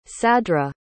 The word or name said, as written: sadra